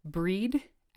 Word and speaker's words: Brigid: And my patron saint is Saint Brigid, or Breed, as it would be pronounced in Celtic, which is how I pronounce it because I am a horrible pagan.